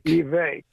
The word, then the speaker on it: Vivek: You listen to Donald Trump, Nikki Haley, Vivek, however you pronounce his name. – Vivek.